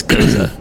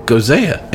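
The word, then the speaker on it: Gose: No, but it's an easy pronunciation. It's Goza. – If it's so easy, then why can't we get it right? Got to get this right, man, because if our listeners don't know how to say this beer, they're going to walk up to a bar and they're going to go, I want a Gozea, and see, they'll get the right beer.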